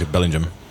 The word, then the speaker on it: Bellingham: Bellingham. Yeah. Well, Bellingham. – How they say it over there is Bellingham.